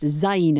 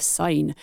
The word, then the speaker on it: Syne: Auld Lang Syne pronounced zine instead of sign.